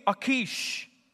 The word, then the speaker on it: Achish: And he basically swore allegiance to the Philistine king, Achish. I know that's how you pronounce it because I Googled it.